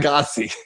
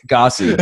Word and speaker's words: Gyasi: What was it? Jasai? – Gossy. – Gossy. It's spelled G-Y-A-S-I, and they're a badass rock and roll band out of Nashville.